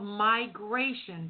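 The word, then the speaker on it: MyGration: Yeah, M-Y in capitals, and then Gration, which is like migration. My migration.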